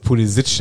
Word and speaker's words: Pulisic: Minute aber, der heißt übrigens wirklich so und nicht Pulisic. Der heißt Pulisic, der Mann.